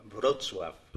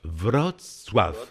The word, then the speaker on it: Wrocław: Par exemple, Wrocław, cela s'écrit W-R-O-C-L-A-W. Mais ça se prononce comment, Jerzy Dudek? – Wrocław. – Wrocław. On peut dire Wrot et soif après. – Tout à fait.